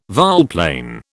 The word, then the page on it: volplane: British English